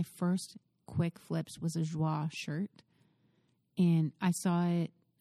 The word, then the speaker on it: Joie: But full disclosure, I used to just call it Joey. – Oh. Joie. – Joie.